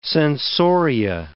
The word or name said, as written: sensoria